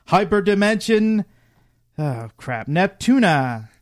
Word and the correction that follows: Neptunia: Yeah, Hyperdimension Neptunia.